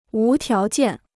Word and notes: 无条件: wú tiáo jiàn

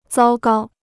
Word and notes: 糟糕: zāo gāo

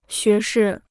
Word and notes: 学士: xué shì

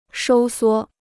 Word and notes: 收缩: shōu suō

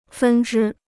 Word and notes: 分支: fēn zhī

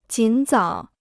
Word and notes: 尽早: jǐn zǎo

